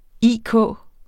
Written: IK

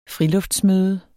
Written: friluftsmøde

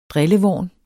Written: drillevorn

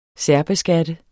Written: særbeskatte

